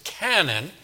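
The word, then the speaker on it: canon (κανών): The Greek word is kanon. We'd pronounce it canon.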